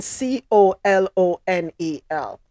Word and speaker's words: colonel: Where is the K E R N E L in C O L O N E L? Is my spelling right? Somebody check me real quick. huh? Colonel, but pronounced colonel.